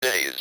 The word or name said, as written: cities